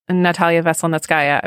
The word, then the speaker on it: Natalia Veselnitskaya: I mean, I think that a both good and sinister development on the Trump-Russia score has been the attention to Facebook over Natalia Veselnitskaya.